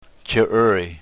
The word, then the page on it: chirry: yurok